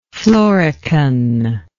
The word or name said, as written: florican